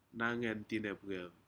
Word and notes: nayentinepcer: Aneityum

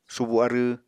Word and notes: subu arww: Apatani

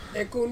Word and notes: kong: Ansus

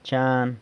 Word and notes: chan: San Lucas Quiaviní

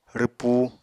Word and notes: rwpu: Apatani

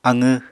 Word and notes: aqw: Apatani

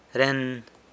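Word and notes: ren: San Lucas Quiaviní Zapotec

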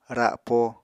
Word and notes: rapo: Apatani

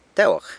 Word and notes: Tewag: sanlucasquiavini

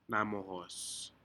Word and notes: namohos: Aneityum

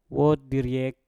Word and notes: wot diryek: mpur

